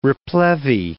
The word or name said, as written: replevy